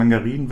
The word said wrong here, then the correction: Menagerie: Wächterin? – Menagerie, ich habe mich für uns gefragt. – Ja, Menagerie, Wächterin. 6 Mana, 5-5er.